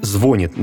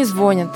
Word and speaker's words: звонит: Поэтому, даже если говорить «звонит» неверно, перенос акцента в середину слова вполне закономерен.